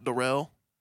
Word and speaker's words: Darrell: My name Darrell is spelled like Daryl, but it's pronounced Darrell.